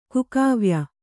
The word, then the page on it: ಕುಕಾವ್ಯ: Kannada